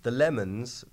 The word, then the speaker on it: Le Mans: Christian Bale is in a movie called Ford v Ferrari about the Le Mans. The Lemons 24-hour endurance race starring Christian Bale. That is how Christian Bale will pronounce it in his accent.